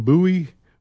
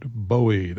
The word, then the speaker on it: Bowie: So he chose a name from America, the Jim Bowie, but he pronounces it Bowie.